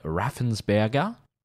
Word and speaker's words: Raffensperger: He had a couple of lawyers and the Georgia Secretary of State, Brad Raffensperger, pronounced in the correct German way.